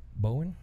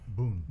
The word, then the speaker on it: Boone: And he also serves as of counsel of the largest law firm in Dallas, Haynes and, how do you say this? Bowen? – Boone.